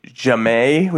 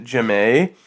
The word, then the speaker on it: Jamie: One of the girls, or one of his characters was a girl named Jamay, which was spelled Jamie, but she pronounced it Jamay.